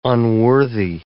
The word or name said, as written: unworthy